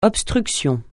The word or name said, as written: obstruction